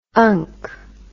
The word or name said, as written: unc